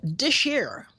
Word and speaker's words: This Here: It's called Dish Air. That's how Cannonball Adderley said it's supposed to be pronounced. The title of this tune is listed as This Here.